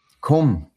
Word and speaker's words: Qom: Launches near, I'm going to butcher this name, Qom, Q-O-M. – Qom, the holy city of Iran.